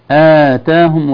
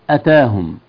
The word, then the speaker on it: آتاهم: أيضا من التنبيهات فرحين بما آتاهم الله، آتاهم وليست أتاهم، والفرق بين الكلمتين واضح في النطق. آتاهم الهمزة ممدودة، أتاهم الهمزة متحركة فقط.